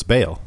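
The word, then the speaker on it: Baal: They say Bale in the game. – Yeah, so it's Bale.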